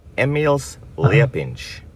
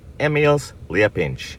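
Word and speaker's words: Emīls Liepiņš: So I am willing to help you out here. Emils Liepinš. So there you go. That is how you pronounce his name correctly. Emils Liepinš.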